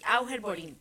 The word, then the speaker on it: Auger Boring: La noticia de la semana tiene que ver con algo que se llama Auger Boring. ¿Lo dije bien? – Sí, Auger Boring.